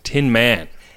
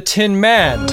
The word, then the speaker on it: Tin Man: I'm glad you pronounced it that way, because everyone else puts the emphasis on the man in this episode. Have you noticed that? When Picard does a log, he's like, "We're on our way to visit the Tin Man."